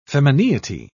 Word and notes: femineity: British English

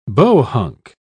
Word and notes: bohunk: British English

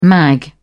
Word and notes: mag: British English

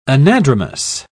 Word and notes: anadromous: British English

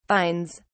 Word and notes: vines: British English

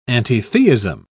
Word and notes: antitheism: British English

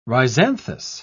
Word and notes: rhizanthous: British English

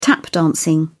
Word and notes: tap dancing: British English

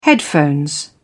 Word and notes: headphones: British English

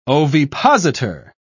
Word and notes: ovipositor: British English

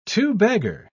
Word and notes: two-bagger: British English

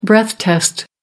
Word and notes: breath test: American English

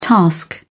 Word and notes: task: British English

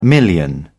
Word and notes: million: British English

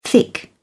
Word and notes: thick: British English

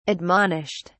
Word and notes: admonished: British English

